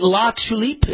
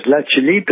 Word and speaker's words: LaTulipe: LaTulipe, is that right? – Well, you're making it sound French, which it is. LaTulipe is the correct pronunciation in French. In English, it's LaTulipe, the tulip in French.